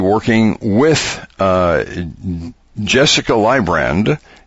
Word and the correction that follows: Luibrand: Okay, Luibrand. Okay, because I did it the Dutch way.